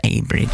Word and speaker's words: Awbridge: Because that Abridge is spelled A-W-B-R-I-D-G-E. Awbridge, right? And I said Awbridge a couple of years ago, and they text back in going, it's pronounced Abridge. Okay, take the W out then.